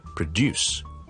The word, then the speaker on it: produce: OK，唔好咁大声，唔好咁大力，就咁 produce。 OK， place more importance on the second syllable，将后面个音节咧再读得强啲，前面嗰个再读得轻啲。